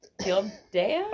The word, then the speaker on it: Gildea: I was like, Gildea? – I always check like everyone's Instagram to like try to remember what their last name is. – I'll put the pronunciation on there. Yeah. Gilde.